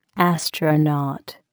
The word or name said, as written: astronaut